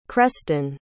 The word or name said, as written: creston